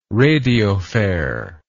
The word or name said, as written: radiophare